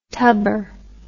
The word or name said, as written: tubber